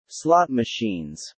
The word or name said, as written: slot machines